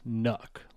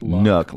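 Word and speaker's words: NUC: Intel says it's pronounced Nook, like Nook, like Nooks.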